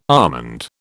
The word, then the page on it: almond: British English